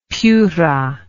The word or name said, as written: piura